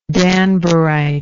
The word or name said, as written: danburite